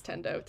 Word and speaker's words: Tendo: I don't know if I'm pronouncing that right. I think it's Tendo.